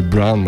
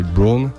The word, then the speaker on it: Braun: E l'incontro tra la cantante, attrice brasiliana Luisa Monteiro e il produttore tedesco Dustin Braun, non so come si pronuncia in tedesco, ma non è Brown, ma è Braun.